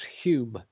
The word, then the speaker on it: Home: It's not home. It's Hume, by the way. – What do you mean it's not home? – The name is spelt H-O-M-E, pronounced Hume. – By who? – It's a Scottish name.